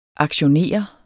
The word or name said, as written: aktionere